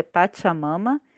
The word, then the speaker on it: Pachamama: No Instagram, Pachamama Editora, você vai escrever Pachamama, mas o pronúncia é Pachamama.